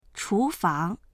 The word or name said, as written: chu2fang2